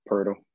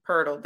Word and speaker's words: Poeltl: Poeltl. Oh, is that how I say it? – Poeltl. – Poeltl. There you go. – Yeah, it's pronounced nothing like it's spelled.